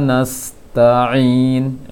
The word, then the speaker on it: nasta'in: Untuk koreksiannya, pengucapan ain kurang masuk pada makhrajnya. Ada beberapa ain tadi, rabbil alamin, alhamdulillahi rabbil alamin, alamin, iyyaka na'budu wa iyyaka nasta'in, ain, jangan nasta'in, kurang pas, kurang bobot pada ainnya, kurang pengucapannya. Nasta'in siratal ladzina an'amta 'alaihim.